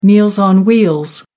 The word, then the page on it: meals on wheels: American English